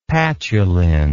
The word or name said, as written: patulin